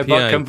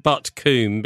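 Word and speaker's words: Butcombe: I know Buckham very well. – You don't pronounce it as coom when you're in the West Country.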